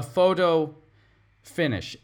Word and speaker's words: photo finish: A photo finish. In American English, we pronounce the T, that middle T, like a D. Photo finish. Photo finish. Or if you want to pronounce the T, photo finish.